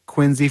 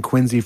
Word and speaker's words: Quincy: And the crowd seemed to be okay with me saying Quincy for the town and Quincy for the Yeah. Man. Oh, yeah. No. The sort of pronunciation idiosyncrasies of my current home state, I can't account for them, but I just know that they're real and people care. So Quincy, Massachusetts, and John Quincy Adams.